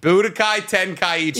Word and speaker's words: Budokai Tenkaichi: Tenkai Budichi. Mm. Buddha Tenkai. – You're closer. – Tenkai Budichi? – You're close. Budichi. Swap them. – Budichi Tenkai. – Budokai. – Budokai Tenichi. – Tenkaichi. – Budokai Tenkaichi. – Yeah, you're like seven.